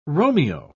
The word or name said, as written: romeo